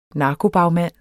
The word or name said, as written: narkobagmand